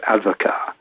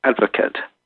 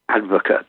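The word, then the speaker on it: advocaat: Which everybody insists on Frenchifying to advocat. But the drink is Dutch, and with the Dutch tending not in the main to overburden themselves with letters they don't pronounce, the name is advocate, as in advocate or lawyer. It was the lawyer's drink.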